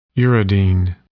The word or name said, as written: uridine